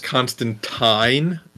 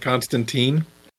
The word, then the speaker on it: Constantine: Which, and just FYI, Chris, I don't know if you remember from the comics, it actually pronounced Constantine never. – In the comics, not Constantine.